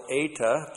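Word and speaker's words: Eta (ἤ): We have the disjunctive particle eta plus the interrogative pronoun t. Eta. Long E, one letter.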